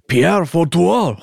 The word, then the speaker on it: Pierre Fouettard: You're saying it wrong. It's Pierre Fouettard. Ho, ho, ho. That's how you say it.